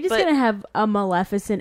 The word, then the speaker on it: Maleficent: So then moving on, we have, and I'm gonna pronounce this correctly, unlike most of the people around, Maleficent.